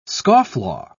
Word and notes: scofflaw: British English

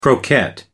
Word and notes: croquette: American English

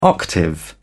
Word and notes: octave: British English

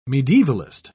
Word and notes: medievalist: British English

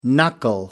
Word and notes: knuckle down: American English

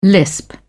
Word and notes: lisp: British English

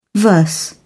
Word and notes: verse: British English

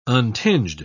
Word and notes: untinged: British English

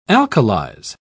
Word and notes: alkalize: British English

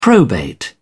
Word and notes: probate: English; British English

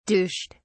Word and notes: douched: British English

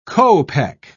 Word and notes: kopek: British English

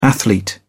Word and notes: athlete: British English

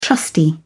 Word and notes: trusty: British English

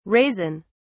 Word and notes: raisin(s): British English

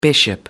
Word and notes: bishop: American English

